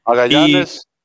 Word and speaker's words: Magallanes: Hoy estamos celebrando la fiesta de San Cristóbal Magallanes, creo que se pronuncia, Magallanes. – Magallanes.